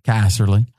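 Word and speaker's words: Casserly: Is that how you say his name? – Caserly.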